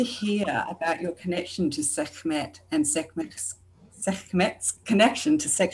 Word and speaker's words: Sekhmet: I'm not sure how to pronounce S-E-K-H-M-E-T. – Sekhmet. Yes, Sekhmet. And you can make it even short, Sekhmet, right?